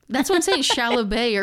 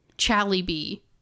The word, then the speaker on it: chalybeate: You said C-H-A-L-Y-B-E-A-T-E? – B-E-A-T-E, yeah. – Oh, what does that B say? – Chalibet. – Chalibet. That's the American pronunciation? No. – No, it's French. Give me the French. – Chalibet. – That's British.